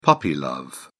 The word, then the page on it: puppy love: British English